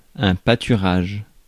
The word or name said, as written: meadow